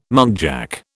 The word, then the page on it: muntjac: British English